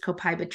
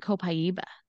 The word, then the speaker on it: copaiba: So there you go, copaiba, same thing. – I pronounce it like you would the way that the Brazilians pronounce it, because I heard somebody from Brazil actually pronounce it once, and I was like, oh, I love that.